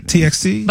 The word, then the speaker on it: TXT: It's TXT. – Did I say that right? TXT.